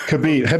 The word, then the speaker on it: Khabib: Khabib.